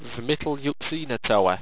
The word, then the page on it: vmitlyuxenatowa: owstwecian